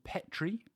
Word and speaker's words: Petrie: There are also accounts that the Irish musician Petrie, I think, pronounced like the dish,